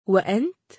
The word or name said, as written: و أنت؟